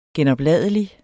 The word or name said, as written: genopladelig